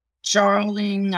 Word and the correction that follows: Darjeeling: I don't know how to pronounce that. Express. – Darjeeling. Yes. Darjeeling.